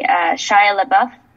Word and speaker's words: Shia LaBeouf: And it would be, let's see if I can even say his name correctly, Shia LaBeouf. – Yeah, you got it.